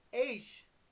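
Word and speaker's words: Aish (אש): Is spelled A-S-H, or the pronunciation is spelled A-S-H, and is pronounced Aish, and it looks like ash, and you're dealing with fire, flames of fire, supernatural fire accompanying theophany.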